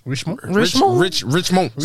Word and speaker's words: Richemont: So basically, I don't know how did you pronounce Rich Mount? – Richemont.